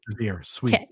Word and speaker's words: Sevier: I just wanted to say it's Sevier County. It's a neighboring county to San Pete, but yeah, we're not going to pronounce it the French way. – Okay, just say it. Sevier, sweet.